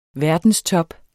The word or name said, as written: verdenstop